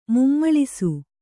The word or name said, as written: ಮುಮ್ಮುಳಿಸು